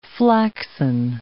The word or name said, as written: flaxen